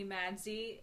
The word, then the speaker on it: Madsy: A lot of people call me Madsy. It's spelled M-A-D-S-Y.